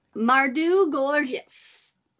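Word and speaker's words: Mawrdew Czgowchwz: And one of his books, Mardu Gorgeous, is how it's pronounced. That's not how it's spelled at all, which was about drag queens and stuff like that.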